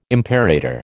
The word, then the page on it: imperator: American English